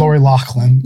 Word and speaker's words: Loughlin: Lori Loughlin.